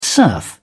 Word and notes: serf: British English

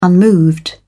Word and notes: unmoved: British English

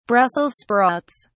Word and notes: Brussels sprouts: British English

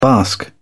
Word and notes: bask: British English

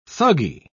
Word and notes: thuggee: British English

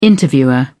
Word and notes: interviewer: British English

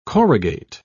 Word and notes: corrugate: British English

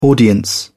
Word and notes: audience: British English

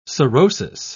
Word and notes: sorosis: British English

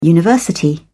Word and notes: university: British English

